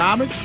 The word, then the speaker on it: homage: Well, yeah, homage? The H is silent. – Is it homage? – Yep.